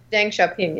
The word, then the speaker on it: Deng Xiaoping: Deng Xiaoping, yes.